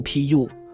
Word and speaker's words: 扶: 扶的古音读 pu，古代传说东海那边有树叫扶桑，扶摇读音 puil。